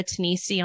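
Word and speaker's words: Tenisi: My last name is spelled T as in Tom, E, N as in Nancy, I-S-I. So I'm Sarah Tanisi on LinkedIn.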